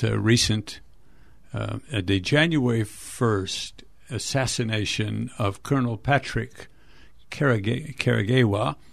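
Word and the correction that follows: Karegeya: And I think I mispronounced that. I'm sorry. Karagea. And so Patrick Karagea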